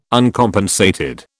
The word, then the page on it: Uncompensated: British English